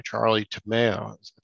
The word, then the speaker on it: Charlie Tomeo: I am joined by Charlie Tomeo.